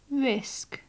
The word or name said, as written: risk